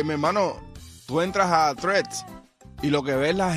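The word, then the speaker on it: Threads: No es treats, es threats.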